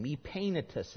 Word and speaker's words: Epaenetus: Epaenetus is how you pronounce that.